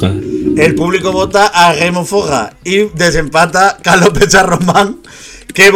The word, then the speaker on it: Forrer: Yo lo pronuncio bien de por sí. Pero encima lo enfatizo. Es Remufog.